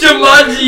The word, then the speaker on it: Jumanji: No, that's Juju Manju. – Wait, how you say it? – It's Jumanji, you fuckwit.